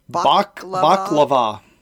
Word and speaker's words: Baklava: Where do you put the emphasis on which syllable? – Baklava. Yes.